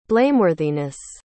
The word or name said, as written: blameworthiness